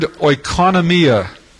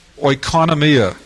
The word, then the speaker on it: oikonomia: The Greek word from which we get our, at least our term dispensation is based upon, is the word oikonomia, oikonomia. We would spell that in English, O-I-K-O-N-O-M-I-A, oikonomia, O-I-K-O-N-O-M-I-A, oikonomia.